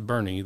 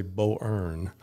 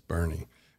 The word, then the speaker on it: Boerne: And one of our early visions was, if a company comes here and they can pronounce Bernie, then we've done our job. – Correct. You don't want to say Bo-Earn, which I've heard many times. I'm like, no, it's not Bo-Earn, it's Bernie.